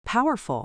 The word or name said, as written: powerful